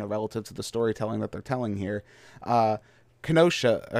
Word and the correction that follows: Khonshu: uh, Keno, um, I can't even say his name. Khonshu. – Khonshu.